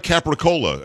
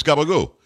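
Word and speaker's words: gabagool: You know, it's like Capricola. And that's what the way Americans say it. It's gabagoo. That's what gabagoo. – It is. Right. Gabagoo. So, I mean, it really is that.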